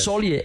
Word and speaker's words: Solier: Não, peraí, como é que se escreve? – Soli, S-O-L-I-E-R. Soli Engenharia. – S-O-L-I-E-R.